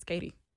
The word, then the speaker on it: Keity: It's K-E-I-T-Y, and when they pronounce it, it's Katie.